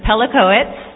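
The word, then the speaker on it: Pelechowicz: E-R-I-C-K-A. My last name, I'm just going to spell it. It's Pelicowitz. It's P-E-L-E-C-H-O-W-I-C-Z.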